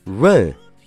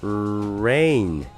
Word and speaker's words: rain: 那你想，如果我们读成以前错误的这个读法 run，那它的元音是不是就变成了？ run run 是不是就读成了一个呜的音的感觉了呀？那肯定是不对的。所以说元音一旦确定了，那么你看剩下的这两个辅音是不是跟跑步的 run 是完全一样的呢？所以说拼起来 rain rain rain。